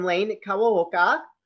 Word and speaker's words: Lane Kawaoka: Please welcome Lane Kawaoka.